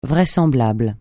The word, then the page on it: vraisemblable: francais